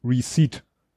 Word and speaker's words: receipt: Und da habe ich dann eben wirklich, ja, es, es spricht sich wirklich Receipt also wenn ich es jemandem erklären wollen würde, wie man spricht, würde ich sagen, ich schreibe dir es auf Englisch und würde schreiben re und sitz auf Englisch.